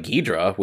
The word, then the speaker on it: Ghidorah: But like Hydra sounds like Ghidra, which is how you pronounce Ghidorah in Japanese.